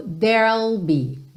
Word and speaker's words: there'll: A gente vai do R direto pro L, porque there não tem o E final pronunciado, certo? Então, there, eu tô com R enrolado, eu já vou pro L da contração do will, ok? There'll be, there'll be, there'll be.